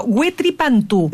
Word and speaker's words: Huetripantu: Huetripantu. – No, perfecto. – Ya, es que aquí decía Huetriu. No, es que la U no se pronuncia.